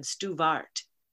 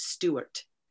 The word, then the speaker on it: Stewart: And so when she got to France, the pronunciation of her name with the W at the center would have been Stuvart. So in order to sustain the pronunciation, she changed the spelling of her name to Stuart to keep it as Stewart.